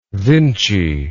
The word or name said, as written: Vinci